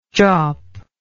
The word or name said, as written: jawp